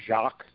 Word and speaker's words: Jacques: Turns out that her father, he's pronounced Jacques, spelled like the French way, J-A-C-Q-U-E-S.